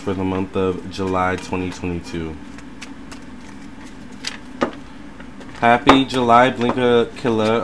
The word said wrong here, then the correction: Blinka Lilla: or Blinka Lilla. Oh my god. Been saying the name wrong this whole time. Blinka Lilla.